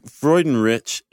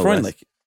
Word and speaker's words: Freudenrich: Freudenrich, is that how we say his name? – Yes. – The author of this article? PhD, no. – Freundlich, isn't it? – No. – I thought it was Craig Freundlich.